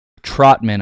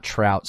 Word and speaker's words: Trautman: Trautman, I believe is how it's pronounced, not Trout, sorry.